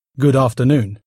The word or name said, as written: good afternoon